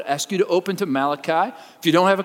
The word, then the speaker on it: Malachi: Some people have mispronounced his name as Malachi, like some Italian prophet, but his actual name is Malachi.